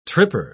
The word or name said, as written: tripper